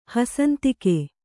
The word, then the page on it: ಹಸಂತಿಕೆ: hasantike